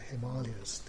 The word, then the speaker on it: Himalayas: The north is the Himalayas, or the Himalayas, given the proper pronunciation, and the great mountains covered in their lower slopes with green forests.